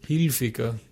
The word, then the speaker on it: Hilfiger: Ja, weil sie es meistens falsch aussprechen, weil eigentlich heißt es Hilfiger. – Hilfiger. – Ja. – Wenn man's Hilf, so wie Hilfe.